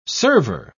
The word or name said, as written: server